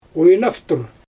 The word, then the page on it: xwjnaftur: Xyzyl